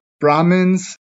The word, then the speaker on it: Brahmins: So Brahmins are the priests.